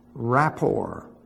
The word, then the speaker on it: rapport: Rapport. Rapport. Pronounce report.